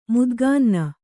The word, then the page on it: ಮುದ್ಗಾನ್ನ: Kannada